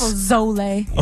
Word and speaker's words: pozole: It's with a Z. – Yeah, but it's like pozole. Yeah. – So you say it with an S. – Pozole.